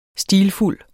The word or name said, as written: stilfuld